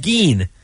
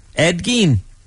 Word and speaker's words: Gein: a real guy named Ed Gein. It's spelled G-E-I-N. I've never heard it, but I do have the pronunciation here. Gein, Ed Gein.